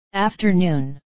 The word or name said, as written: afternoon